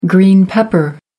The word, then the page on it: green pepper: American English